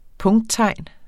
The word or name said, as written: punkttegn